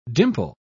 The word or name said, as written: dimple